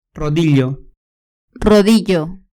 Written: rodillo

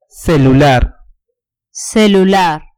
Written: celular

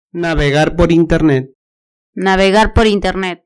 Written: navegar por internet

